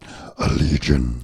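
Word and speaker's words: Allegaeon: Allegiant will be right back on the Ludini Rock and Roll Circus. – We'll be right back. – That's the band Allegion. You have to say it like that. Allegion.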